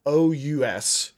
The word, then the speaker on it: arenaceous: And the piece of vocabulary that I thought might entertain you today, Phil, is a word called arenaceous. Arenaceous. Yeah. It's spelled like the Celtic female name Erin, followed by anaceous. Erinaceous.